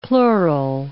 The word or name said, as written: pleural